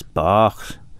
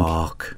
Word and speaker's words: Bach: Yeah, now I would drop a point for Bach, because it's not Bach, it's Bach. And you've just got to remember with Manx, the vowel sounds are always about four or five times longer than you might think. – Bach.